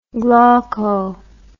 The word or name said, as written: glauco